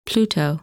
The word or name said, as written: Pluto